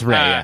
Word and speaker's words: Rhea: Ray? I don't know how to say her name. – It's Ray, So that's Hilda.